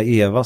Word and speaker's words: EVA: Det här Eva ska man inte säga egentligen, eller? – Nej, eller vi svenskar kanske säger Eva, men det korrekta uttalet är EVA, eller EVA.